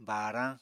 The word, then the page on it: baarañ: apatani